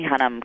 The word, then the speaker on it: Hunnam: The rest of this cast, we got Colin Farrell, Charlie Hunnam. I'm saying that right? Charlie Hunnam, because I never... – It is, right, yeah.